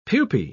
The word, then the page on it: pupae: British English